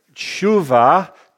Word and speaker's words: Shuvah: Shuv, as you know, is the root of the word Shuvah, T-S-H-U-V-A-H. It is not Teshuvah. It goes all together and it's Shuvah. That's how you pronounce that, just so you know.